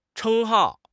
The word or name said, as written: 称号